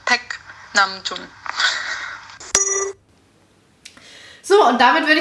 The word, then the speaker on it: Paik: Äh, allerdings spricht man den nicht Paik aus, sondern Paik. und jetzt habe ich auch mal das Hangul, also auf Koreanisch geschrieben und es ist halt wirklich ein E-Sound, eigentlich sein Paik.